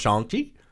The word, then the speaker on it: Shang-Chi: You were the one that came on the pod and was like, man, we need to do better because it's pronounced Shang-Chi.